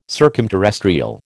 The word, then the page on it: circumterrestrial: American English